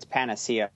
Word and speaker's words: panacea: Guys, it's Panacea. It's how the dictionary pronounces it, just in case.